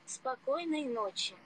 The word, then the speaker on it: Спокойной ночи: Спокойной ночи. – Спокойной ночи. – Spokojnoj noci.